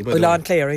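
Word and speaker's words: Oileán Cléire: Am I saying that correctly? – Ilan Clara, yeah.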